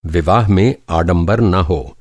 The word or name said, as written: आडंबर